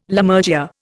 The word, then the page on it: Lammergeyer: Indian